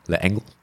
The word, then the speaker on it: L'Engle: That is the autobiography of someone called Madeleine L'Engle. L'Engle.